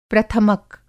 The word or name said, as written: प्रथमक